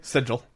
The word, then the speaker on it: Sigil: So, in addition to the free megawad, Sigil, am I saying that correctly? – Sigil.